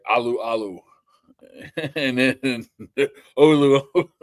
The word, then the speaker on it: Alualu: It was Olualu and Alualu. – Alualu. Alualu.